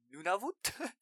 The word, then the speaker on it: Nunavut: Nunavut? – Yeah, I think it's like an Inuit name.